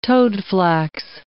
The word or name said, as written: toadflax